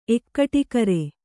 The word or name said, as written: ಎಕ್ಕಟಿಗರೆ